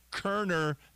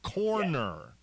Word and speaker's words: Koerner: You know, for those that don't know who Matt Kerner is, first of all, his last name is K-O-E-R-N-E-R, but it sounds like Kerner, not corner.